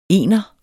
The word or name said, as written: ENERNES